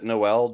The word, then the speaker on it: Noel: A handful of us intentionally pronounce it Noel during December just because we think it's funny.